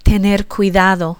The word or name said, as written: tener cuidado